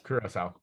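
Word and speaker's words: Curaçao: Curacao. Yeah. – Really? – There's a cedilla. You know, like you don't say Francis, you say Francais.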